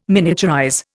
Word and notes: miniaturize: Indian

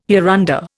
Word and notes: hereunder: Indian

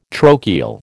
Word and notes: trochal: American English

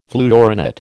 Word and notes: fluorinate: American English

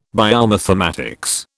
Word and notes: biomathematics: British English

